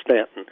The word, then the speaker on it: Staunton: That's that town that looks as if it ought to be pronounced Staunton, but it's Staunton.